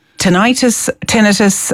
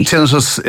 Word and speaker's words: tinnitus: Is it tinnitus? Am I saying it correctly? – Tinnitus is the normal pronunciation. The Americans generally say, or sometimes say tinnitus, but tinnitus.